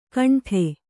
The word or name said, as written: ಘಂಟೆ